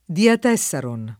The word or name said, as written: diatessaron